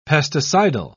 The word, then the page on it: pesticidal: British English